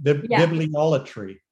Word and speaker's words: bibliolatry: Bibliolatry. – That's right. That's how you say it. I couldn't remember how to say it.